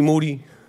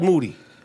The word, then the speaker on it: Moody: Moody. – That's right. – Last name, it rhymes with duty.